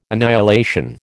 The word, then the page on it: annihilation: American English